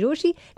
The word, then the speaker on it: रोशी: मेरा नाम है मिर्ची रोशी, नाम की स्पेलिंग है ROCHIE.